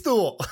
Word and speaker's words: Thule: They should have, don't spell it like it rhymes with mule if you're gonna pronounce it Thule.